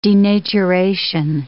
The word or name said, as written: denaturation